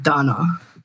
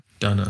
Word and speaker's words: DVNA: It's pronounced Dana. – Dana.